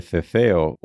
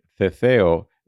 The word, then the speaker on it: ceceo: Remember that this last category, ceceo, is spelled C-E-C-E-O. With the ceceo or lisp, all three consonant letters sound like the soft TH sound in English.